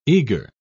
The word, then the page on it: eagre: British English